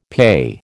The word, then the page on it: PAYE: American English